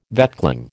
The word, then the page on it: vetchling: American English